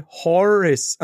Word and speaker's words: Horace: Also auf Englisch wird das meist wie Horace. Also H-O-R. – So wie Horace Race. Horace.